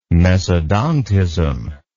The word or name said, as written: mesodontism